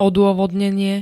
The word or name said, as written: odôvodnenie